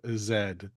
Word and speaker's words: Zed: We say it correctly. Zed. None of this Z bullshit.